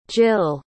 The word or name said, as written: jyll